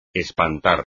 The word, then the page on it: espantar: espagnol